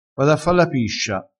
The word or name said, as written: fa la piscia